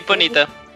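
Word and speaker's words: Ponyta: Eh, ¿Cómo se pronuncia? ¿Ponita? – Sí, Ponita.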